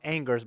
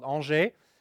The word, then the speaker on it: Angers: It looks like it's pronounced Angers, but the town is called Angers.